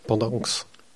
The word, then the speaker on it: Pendanx: Donc c'est le carnet de guerre imaginaire d'un combattant de la Légion tchèque, de Jean-Denis Pendant et Chris. – Pendanx. – Pendanx.